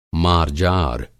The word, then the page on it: मार्जार: Hindi